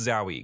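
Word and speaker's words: Zawe: I believe it's Zowie, because it's like pronounced wow, but without a Z, so it's Zow, and then the E is pronounced E. It's Zowie Ashton.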